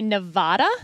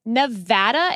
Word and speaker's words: Nevada: Because a lot of politicians would say Nevada. – Wrong. – And that is wrong. – Wrong. It is Nevada. And I had to train myself to say Nevapple so I could pronounce the A right.